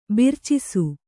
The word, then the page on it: ಬೆರ್ಚಿಸು: bercisu